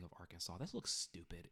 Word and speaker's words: Arkansas: Arkansas must be pronounced Arkansas. And that's spelled A-R-K-A-N-S-A-W. Arkansas.